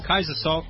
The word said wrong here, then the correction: Casey Soule: Casey Soule. That's how you pronounce it.